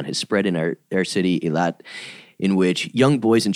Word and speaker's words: Eilat: Earlier this year, in response to Tel Aviv anointing itself the dog-friendliest city, a group of 12 rabbis from Eilat, am I saying that right? – Eilat.